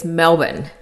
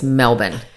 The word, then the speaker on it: Melbourne: Yeah, so it's Melbourne. – Melbourne. – Yeah, that's better. That's much better. You kind of have to cover up the second vowel. So it's Melbourne. It's like BN, no OU.